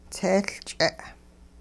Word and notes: tseeLch'e': Hupa

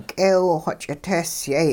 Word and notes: k'eew-xw ch'itehsyay: hupa

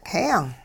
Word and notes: heeyung: hupa

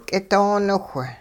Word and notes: k'idooniwh: hupa

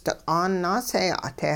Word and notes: diq'aan naaseeya'te: hupa

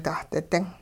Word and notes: dahdi-ding: hupa

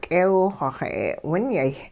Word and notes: k'eew-xw xa'e'winyay: hupa

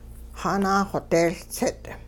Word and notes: xaanaaxodeeLtsit': Hupa

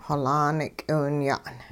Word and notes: xolaan k'iwinya'n: hupa

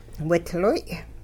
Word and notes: widloy': Hupa

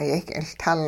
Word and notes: yehk'iLtul: hupa

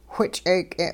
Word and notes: which'eek'e': Hupa

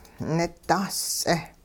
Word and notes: nidaas-ts'eh: hupa

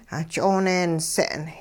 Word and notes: 'ah ch'ooneense'n: hupa

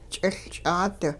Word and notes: ch'iLch'aat: Hupa

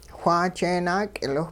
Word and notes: whaa jeenaak'iliwh: hupa

